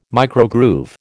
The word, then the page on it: microgroove: American English